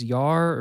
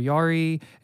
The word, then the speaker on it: yare: The first one is yar or yari. It's spelled Y-A-R-E, but it means morally reverent.